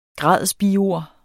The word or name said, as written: gradsbiord